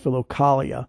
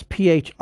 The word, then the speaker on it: Philokalia: Also, I've heard it pronounced Philokalia, and that's Philokalia.